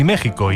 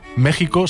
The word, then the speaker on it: México: Entonces, si México y Texas se pronuncian así, ¿por qué se suelen escribir con X?